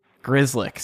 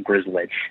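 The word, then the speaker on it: Grzelcyk: It's Grizz, like Grizz, and then Lick, like Lick. – Grizzlick.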